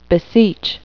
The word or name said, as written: beseech